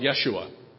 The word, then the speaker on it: Yeshua: Among his contemporaries, he would have been called Yeshua, which is how you pronounce his name in his native Aramaic tongue.